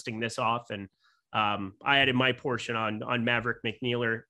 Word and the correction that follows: Maverick McNealy: Maverick McNealy, I should be able to say his name.